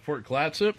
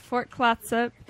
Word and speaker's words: Fort Clatsop: Fort Clatsop? – Yes, Fort Clatsop, the Lewis and Clark Museum.